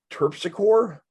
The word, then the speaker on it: Terpsichore: Terpsichore.